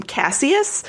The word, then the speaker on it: Cassius: Because in the first episode, he goes to see Cassius and he calls him Cassius. And the dude's like, no, my name's Cassius.